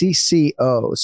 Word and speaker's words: CC0: Written CC0, but pronounced CCO.